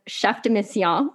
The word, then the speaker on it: Chef de Mission: Congratulations on being named Team Canada's Chef de Mission.